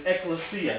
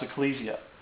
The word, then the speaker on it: ekklesia: The other word is ekklesia, and you may have heard that before. It's also it can also be pronounced ecclesia.